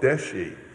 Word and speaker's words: Deshi: The provincial aspects are called Deshi, D-E-S-H-I, long I. And the word simply means provincial or local. The elementary is known as Marga, M-A-R-G-A with a long A, which means path.